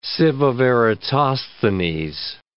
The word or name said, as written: sieve of Eratosthenes